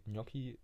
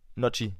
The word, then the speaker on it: Gnocchi: Ich, ich hoffe, du kennst auch solche Leute, die, die Gnocchi nicht richtig aussprechen. Ja. Was sagen die dann? – Nocci. Nocci? Ja.